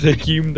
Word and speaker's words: Hakeem: Does machine rhyme with Hakeem? – It's Hakeem the machine.